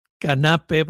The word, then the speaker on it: Canape: Esmeralda Canape, porque no tiene acento en la E, Okay.